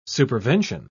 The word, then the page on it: supervention: British English